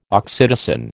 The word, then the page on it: oxytocin: American English